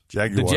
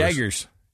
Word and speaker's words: Jaguar: Jaguars. – The Jaguars. Yeah. The Jaguars. – Yep. – You're supposed to say it like the people from England. Jaguar.